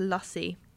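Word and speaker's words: lassi: Apparently it's meant to be pronounced lassie.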